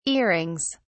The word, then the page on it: earings: British English